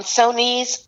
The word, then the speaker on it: Sonee: Sonee's sports report.